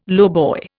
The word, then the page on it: lowboy: Indian